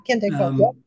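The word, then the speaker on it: Dychtwald: Yeah, Ken Dykewald.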